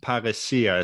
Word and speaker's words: Parresia: I've heard about every possible way to pronounce it. Parresia is how I would say it.